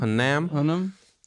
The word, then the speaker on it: Hunnam: We got Charlie Hunnam. I think that's how you say his name? – Mm-hmm. – Hunnam.